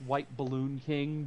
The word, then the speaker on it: WHTBLLNKING: And my Twitter handle, it's pronounced White Balloon King, but it's spelled WHTBLLNKING because Twitter gave me a character limit.